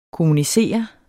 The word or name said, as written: kommunicere